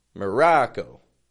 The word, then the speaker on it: Morocco: Putting a schwa in the beginning. The ra. A short O sound. Ra. Real strong. And then ko. Quick, fading, tense O. Morocco.